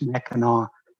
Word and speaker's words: Mackinac: First of all, about that C, I'm from Michigan. It's always pronounced Mackinac, no matter how it's spelled. So Mackinac Straits, Mackinac Bridge, Mackinac Island, all spelled C, but pronounced with the W.